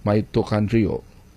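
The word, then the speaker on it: mitochondria: Ano mag-pronounce ni? Mito Chondria. Pero mag-pronounce, Mito Country. Mito Countryo. Mito Countryo.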